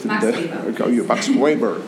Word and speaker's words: Weber: Max Weber.